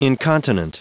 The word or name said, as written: incontinent